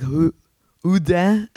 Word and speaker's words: Houdin: They're very difficult to pronounce in French. – Jean-Eugène Robert Houdin. – Yeah, there you go. It would be more like, probably like Houdin.